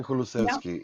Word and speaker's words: Kulusevski: Kulusevski, am I saying that right? – Dejan Kulusevski, yeah.